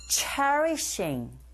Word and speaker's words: cherishing: Let's get started with the first listening point, that is cherishing this time. 체리가 아니라 여러분, 쉬, 쉬, 쉬. 요 발음이요. 쉬운 것 같지만 잘못 발음하면 체리처럼 체, 우리나라 말 어에처럼 체 이렇게 들리거든요.